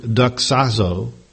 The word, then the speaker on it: doxazo: Glorify here is translated from the Greek word doxazo, doxazo, D-O-X-A-Z-O.